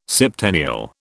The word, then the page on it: septennial: British English